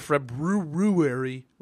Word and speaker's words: February: Is there any funny at 9th of February with an R, say it properly.